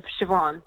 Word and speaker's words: Siobhan: Because my name is spelled in the Irish way of Siobhan. So it's S-I-O-B-H-A-N, like B for Bravo, H for hotel. It's sort of weird. So there is no S-H in there.